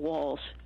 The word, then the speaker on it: Walz: You know, there's how do you spell Walls? – W-A-L-Z. – W-A-L-L-S. – No, no. – Thank you. – It's pronounced as if it was LLS, but it's LC.